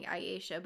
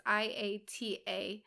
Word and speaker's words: IATA: Once you've made $5,000 in sales, you can get an IATA, I believe is how you pronounce it. I was saying IATA before, but it's IATA.